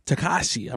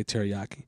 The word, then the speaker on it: Tekashi: So, Tekashi, I believe it's pronounced. I used to say it wrong. I used to say teriyaki.